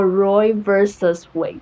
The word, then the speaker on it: Roe v. Wade: Roe and Wade 的故事的时候，其实这个我们可以看到说这个字啊是 Roy v. dot 然后 Wade，然后所以这应该要念成 Roy v. Wade，嗯，哦 Roy versus Wade，哦，好，这是一个英文小小角落的概念。